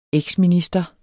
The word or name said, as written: eksminister